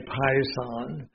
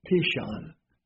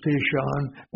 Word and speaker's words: Pishon: And the name of the first is, we would say Pison. If we were pronouncing it from Hebrew, it would be Pishon. Yeah. Okay. So the name of the first is Pishon.